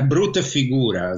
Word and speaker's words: brutta figura: He has done a brutta figura, as they say in Italy, a very bad...